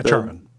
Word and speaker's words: Charon: So that's the beautifully animated game where you are playing as, I forget the pronunciation, but Charon? – Yeah, Charon.